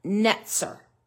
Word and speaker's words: netzer: So it turns out that the word branch in Hebrew is pronounced netzer.